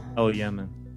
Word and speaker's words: Elyaman: Elyaman.